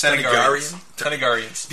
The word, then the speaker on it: Thanagarians: But Thanagarians. How do you say this? – Thanagarians.